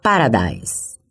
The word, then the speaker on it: Paradise: Inclusive, em um dos parágrafos, a autora deixa claro, na voz do personagem que chefia a vigilância do condomínio, que, embora o nome do lugar seja Paradise, paraíso em inglês, a pronúncia correta vem mesmo carregada da afetação comum aos seus moradores. Se fala Paradise.